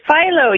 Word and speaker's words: Philo: It's Philo.